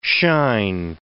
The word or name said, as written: shine